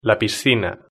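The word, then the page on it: la piscina: Spanish (Spain)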